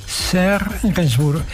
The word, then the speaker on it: Serge Gainsbourg: Escrito por Serge Gainsbourg. ¿Lo pronuncio bien? Serge Gainsbourg, perfecto.